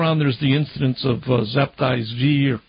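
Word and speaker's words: Sabbatai Zevi: You correctly mentioned Zabtai Zvi, who had several hundreds of thousands of followers in the end.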